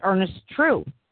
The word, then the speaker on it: Truex: Bobby told me earlier that his name, T-R-U-E-X. I say Truex, but Bobby thinks it's pronounced, the X is silent, and it's Ernest True.